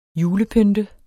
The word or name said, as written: julepynt